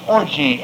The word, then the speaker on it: orge: Now the word in the Greek is orgy, O-R-G-E, with a long E.